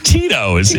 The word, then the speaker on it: Tito's: And in the email, it had the pronunciation of Tito's, in case I didn't know. – Good. – T-E-E. Dash T-O-O-S. – Uh-huh. – Tito's.